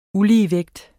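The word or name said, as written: ULIGEVÆGT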